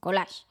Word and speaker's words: collage: Joder, collage también es una palabra, pero collage es, se dice así, collage.